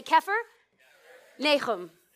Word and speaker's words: Capernaum: So when we pronounce Capernaum, we call it Capernaum. But since you Gentiles don't know Hebrew, and remember, I am 1% Jew, I may teach you the correct way to say this city's name. And you're going to repeat after me. Repeat after me. Say Kephar Nahum. Now, you didn't get the ch in there.